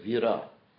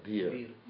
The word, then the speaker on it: vira: It's Prabhupada's Bengalicized pronunciation, and even wrote it that way for me, of the word Sanskrit word vira. Vira means heroic in Sanskrit, or power, or potency, and stuff like that. And I think in Hindi it's similar, bir. So, the Prabhupada would pronounce it, bir, the Sanskrit V like a B, that's a Bengali pronunciation.